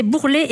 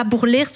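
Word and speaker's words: Bourlers: Et donc, comme on nous dit un auditeur, le village, c'est Bourlet et pas Bourlère, sur le S ne se prononce pas.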